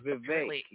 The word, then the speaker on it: Vivek: Yeah, Vivek. Yeah, he pronounced it like cake.